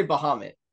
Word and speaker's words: Bahamut: How is it pronounced? Yeah. That's another one of those ones. – I say Bahamut. – Yeah, I do too. – People say like Behemoth or something like that.